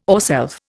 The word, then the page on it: ourself: Indian